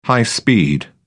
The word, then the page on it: high-speed: British English